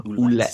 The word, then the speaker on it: Ouellet: Pierre Carl Ouellet. – So you do pronounce the T.